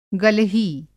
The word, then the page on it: गलही: Hindi